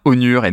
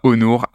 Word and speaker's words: Onur: Je refais ma petite blague, du coup, on dit bien Honor et non pas Honor.